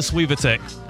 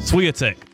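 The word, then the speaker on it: Świątek: Iga Swiatek. Swiatek. I always say it wrong the first time, but Swiatek has beaten Gauff 10 out of the last 11 times meeting her.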